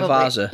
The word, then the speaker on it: Javazza: I think you're probably Javazza. – You're probably J-A-V-A-Z-Z-A. – Yeah, you're probably right.